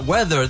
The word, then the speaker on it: whether: No, we're talking about weather. This is W-H-E-T-H-E-R. But the pronunciation is the same as weather, el tiempo.